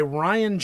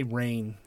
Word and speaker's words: Rian: This is the new one by Rian Johnson. I always want to say Rain because it's spelled R-I-A-N.